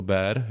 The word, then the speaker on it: Bear: Uncut Footage of a Bear, nebo Bear, jako medvěda prostě. – Mm-hmm to vyslovuje jako pivo hrozně často.